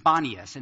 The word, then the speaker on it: Banias: It was on the site of an ancient city, but that then it was known as Panias, P-A-N-E-A-S, and now it's pronounced Banias, and so that part of Israel now is called Banias, not Caesarea Philippi. It's named Panias after the Greek god Pan, or Pan, P-A-N.